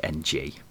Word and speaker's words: Ng: I believe it's pronounced NG, not Ing. – It's Perry Ing. – No, it's Perry NG.